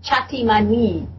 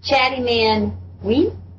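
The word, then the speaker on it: Chintimini: How do you pronounce this? Chatimanwi Festival? – Oh, yeah. It's called the Chatimanwi. Like, Chatty Man We? – Okay.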